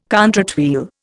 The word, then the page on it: contrate wheel: Indian